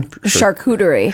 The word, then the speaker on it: charcuterie: Charcuterie. – Charcuterie trays. – Char, like char, the name. – Yeah. – Cooter. – Cooter.